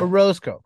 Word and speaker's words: Orozco: is it Orozco? Is that how I'm pronouncing it? – Orozco, correctly? – Yeah. – Orozco.